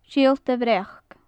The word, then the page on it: siolta bhreac: gaelic